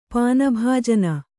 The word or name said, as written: ಪಾನಭಾಜನ